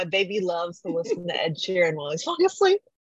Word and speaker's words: Ed Sheeran: I love Ed Sheeran. Oh. Did I say that correctly? – Yeah. – Yes.